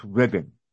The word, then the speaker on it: Reading: I believe that's pronounced Reading. – Yeah, Reading, excuse me, thank you. – R-E-A-D-I-N-G, go ahead.